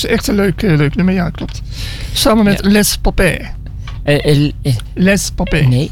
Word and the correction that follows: Les Papés: Nee, de papé. S spreek je niet uit op dat moment. Okay. Les Papés. Okay. Les Papés moet je eigenlijk zeggen. Want de S, die spreken... De Fransen spreken de S namelijk zelden of nooit uit.